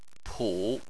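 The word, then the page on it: pu: tone 3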